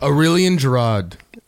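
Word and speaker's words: Aurélien Giraud: Aurelian Gerard.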